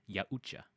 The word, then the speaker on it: Yautja: They have a pronunciation. It's called Yautja.